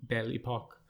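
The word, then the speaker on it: Belle Époque: was kind of more born from the Bohemian Belle Epoque. Did I say that right? – Yes, darling. Gorgeous.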